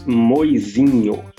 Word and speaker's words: Moisinho: Edson, how do you say your last name, Edson? – It's Moizinho. – Moizinho, okay.